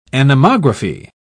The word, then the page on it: anemography: British English